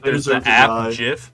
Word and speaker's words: GIF: Okay, so this is kind of like a group thing that I want to talk about, because like GIF is pronounced with a hard G, like yeah, it is.